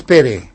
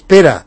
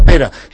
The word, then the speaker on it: Pere: Dic, bé, però no me digas Pere. ¿Por qué? Digo, es Pera. Dice, pero aquí pone Pere. Dice, pero se pronuncia Pera.